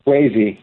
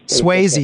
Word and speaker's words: Swasey: Ambrose Swasey. How do you say that? – Swayze. – Swayze, excuse me, professor of physics, professor of astronomy, and chairman of the Department of Physics at Case Western Reserve University.